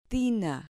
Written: tina

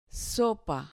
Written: sopa

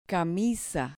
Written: camisa

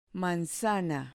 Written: manzana

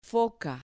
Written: foca

